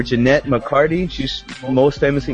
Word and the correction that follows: McCurdy: known for, I have to bring up her name again because I'm probably mispronouncing it. She's most known for iCarly. It's a show on Nickelodeon. You familiar with her names? I'm sorry, Jeanette McCurdy.